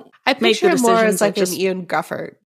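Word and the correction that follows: Gruffudd: Griffith? It's Griffith. – Is it? Okay. – It's spelled Griffud, but he's Welsh, so it's Griffith.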